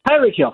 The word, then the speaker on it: Tyreek Hill: Tyreek Hill? – Tyreek Hill, thank you.